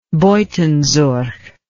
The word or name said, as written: Buitenzorg